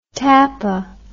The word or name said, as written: tappa